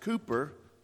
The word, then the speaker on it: Cowper: William Cowper, that I recently learned should be pronounced Cooper in this case,